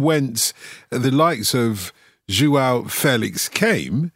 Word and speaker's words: Félix: you're giving us pronunciation lessons, it is still Felix, is it? Because I heard you kind of do a Felix kind of Felix. Thing. – Felix. Felix. Yeah. – So not only do we have to get our heads around Joao, but as well Felix as well. Yeah, there's the little accent on the E.